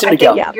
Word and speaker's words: Symdeko: It is Symdeko. – You're saying it the right way. – Symdeko. – Yeah. – Symdeko rhymes with Kalatico.